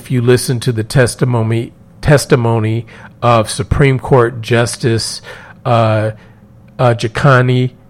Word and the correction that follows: Ketanji: I just messed up her name, and I cannot think of the correct pronunciation right now, but Supreme Court Justice Brown Jackson, Ketanji Brown Jackson.